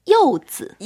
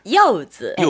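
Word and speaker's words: 요즈 (柚子): 네, 자몽 중국어로는 요즈. – 요즈.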